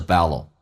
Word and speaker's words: battle: 没有必要说那么字正腔圆，因为它非实义词汇。You've already lost the battle. Battle, T 跟 L 在美式发音发成 D 跟 L 叫 battle, 都那样听懂。我们看下面一句。When you have to prove something.